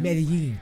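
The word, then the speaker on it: Medellín: Uh, is that Medellin? – Medellin. Medellin.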